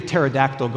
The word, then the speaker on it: pterodactyl: Um, why can't you hear the pterodactyl go into the bathroom? Think about how they spell pterodactyl. Yeah, how do you spell pterodactyl? Yeah, the P is silent.